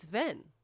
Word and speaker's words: Hven: It's H-V-E-N. I don't know how to... I want to say like... I'm doing Jewish. – I don't think they pronounce the H, right? – Ven? – I think it's Ven.